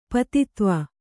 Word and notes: ಪದಾಧ್ವ: kannada

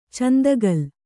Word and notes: ಚಂದಕಲ: candakala

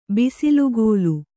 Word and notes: ಬಿಸಿಲುಗೋಲು: bisilugōlu

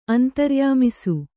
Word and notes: ಅಂತರ್ಯಾಮಿಸು: Kannada

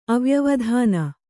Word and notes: ಅವ್ಯವಧಾನ: avyavadhāna

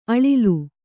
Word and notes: ಅಳಲು: aḷalu

